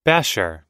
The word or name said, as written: basher